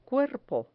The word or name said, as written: cuerpo